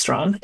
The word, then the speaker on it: Strachan: And he spelled Strachan, but pronounced Strawn.